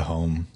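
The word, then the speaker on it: Homme: Is it Hom? Is that how do you pronounce his name? – I would default to Hom.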